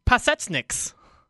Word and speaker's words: Pasecniks: Oh, we've got a draft pronunciation guide. I tweeted it out last week, but it is at the top of our show plan. It's Anzej Pasecniks.